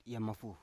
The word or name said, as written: yamafu